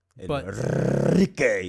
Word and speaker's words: Enrique: Enrique, you really got to roll those R's.